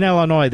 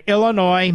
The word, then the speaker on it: Illinois: Oh, Mike is a truck driver in Illinois. The S is silent, Mr. Producer. Mike, a truck driver in Illinois.